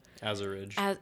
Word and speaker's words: Azuridge: Azuridge. – Azuridge. Some people say Azure. Like they say it like... – Yeah. Yeah. Really dry out the U. – Yeah. – No. Azuridge.